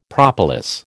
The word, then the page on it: propolis: American English